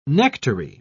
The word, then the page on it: nectary: British English